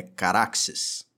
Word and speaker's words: Caraxes: Carachas. – É, Carachas. – Ajeite esse Carachas aí. – Segundo velhos tomos em alto valeriano, a correta pronúncia do dragão do Daemon é Caraxes.